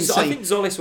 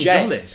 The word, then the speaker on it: Jolis: I think Zolis worked, but apparently it's J. Jolis. Apparently it's Zolis with a hard J. Jolis. – Jolis. Like a jolly.